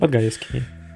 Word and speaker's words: Подгаевский: А когда, как, если говорить про того же Подгаевского, Подгаевского, Подгаевского, вечно забываю ударение. – Подгаевский. – Подгаевского, да. А